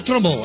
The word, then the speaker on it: Trombeau: Trombeau si pronuncia Trombò. – Ecco, alla francese.